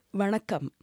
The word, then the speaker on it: Vanakkam: Vanakkam, as we say in Tamil. Okay. Say, say that again for me. Vanakkam.